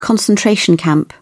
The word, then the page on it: concentration camp: British English